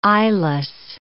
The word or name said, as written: eyeless